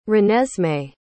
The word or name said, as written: renesmee